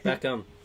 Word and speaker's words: Beckham: Beckham. I think the H is silent.